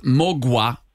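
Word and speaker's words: Mgła: Och jag, jag har fått lära mig att det uttalas mogwa och betyder dimma.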